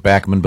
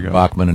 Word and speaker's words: Bachman: Yeah, Randy, he pronounces it Bachman, but Bachman in the United States, is a terrific guy and a great player.